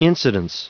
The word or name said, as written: incidence